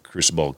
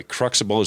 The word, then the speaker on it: Cruxible: So that's how Crucible kind of, by the way, Cruxible is the phonetic way to pronounce it. But for some reason, I've always called it Crucible.